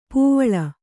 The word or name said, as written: ಪೂವಿಲ್